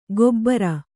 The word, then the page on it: ಗೊಬ್ಬರ: gobbara